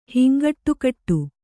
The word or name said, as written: ಹಿಂಗಟ್ಟುಕಟ್ಟು